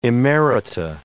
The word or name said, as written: emerita